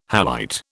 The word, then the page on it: halite: British English